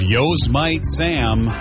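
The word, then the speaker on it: Yosemite Sam: He once said his favorite cartoon character was Yo's Mite Sam, Neil Freeman. – That was close. – Phonetically, you were correct.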